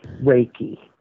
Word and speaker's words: Reiki: Reiki or Reiki? How do you pronounce that? – Reiki. – Reiki. See, I knew mm-hmm. I should have butchered it. Reiki.